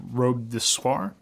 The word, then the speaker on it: Robe de Soie: I'm going to pick the Hermès Robe de Soie. Did I say that right? – I think so. We'll say so.